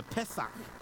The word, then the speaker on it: Pesach: And I think the Israelites, one of the reasons God instituted this Passover, and it was an act of faith, the Pesach is how they say it in Hebrew,